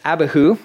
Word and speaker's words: Abihu: So, verse 2, the names of Aaron's sons were Nadab, the oldest, Abihu, that's how you say his name, Eleazar, and Ithamar.